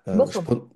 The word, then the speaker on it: Bochum: J'ai noté que vous aviez un doctorat en neurosciences obtenu en Allemagne à l'université de Bucom. – Bochum. – Oui, c'est ce que j'allais dire, je prononce très probablement mal.